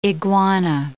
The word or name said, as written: iguana